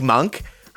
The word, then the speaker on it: Monk: Malik Monk, whose name rhymes with dunk, as has been pointed out, I think on the No Dunks podcast, he's a real terrific dunker.